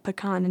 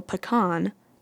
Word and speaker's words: pecan: So pecan puts the stress on the second syllable and that's like the French version versus like pecan, which is the Englishified version because it switches the stress to the first syllable.